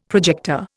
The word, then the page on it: projector: Indian